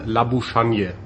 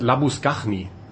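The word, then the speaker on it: Labuschagne: Sein Name auf Deutsch einfach gelesen Labuschanje oder mhm. Labuschakne eigentlich mit GNE hinten. Dann sagt mir der südafrikanische Freund, ja, den spricht man Labuschanje oder sowas aus. Und irgendwann nach ein paar Sendungen meldet sich jemand bei uns, der sagt, ihr sprecht diesen Spieler komplett aus, falsch aus. Da heißt Labuschachni.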